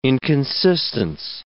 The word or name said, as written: inconsistence